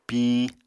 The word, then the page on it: piñ: Apatani